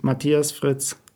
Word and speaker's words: Matthias Fritz: My name is Matthias Fritz.